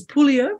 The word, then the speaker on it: Pouliot: Okay, so it's Pulio with a silent T.